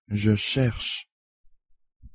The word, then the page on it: je cherche: French